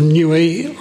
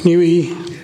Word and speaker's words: Niue: I'm representing the government of Niue, or Niue, depending on pronunciation.